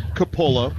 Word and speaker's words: Coppola: To hear that his name was pronounced Capola, because it's spelled C-O-P-P-O-L-A.